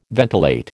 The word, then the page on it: ventilate: American English